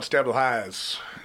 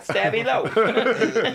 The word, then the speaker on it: Stabilo: I'm just imagining just every time you talk to anyone in that band, you mispronounce a Stabilo. No, Stabilo. Yeah, Stabilo. – Stabilo. – Stabilo. Not Stabby High, Stabilo.